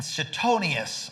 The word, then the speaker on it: Suetonius: There are Roman historians, I'll see if I can pronounce their name right, Tacitus, T-A-C-I-T-U-S, and Suetonius, I got that one right, Roman historians have recorded the belief of a worldwide king would be born and he would rule.